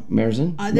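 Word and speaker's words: Märzen: Oh, Marzen. There's A with the thing on it, so it's a long A.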